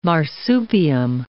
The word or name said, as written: marsupium